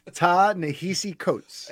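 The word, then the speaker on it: Ta-Nehisi Coates: Ta Nehisi Coates.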